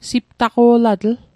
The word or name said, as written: siptaqo'latl